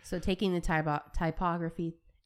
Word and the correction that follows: topography: and topography. Sorry, I keep saying it wrong.